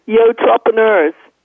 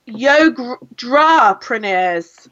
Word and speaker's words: Yotrepreneurs: Yotrepreneurs. – Yotrepreneurs.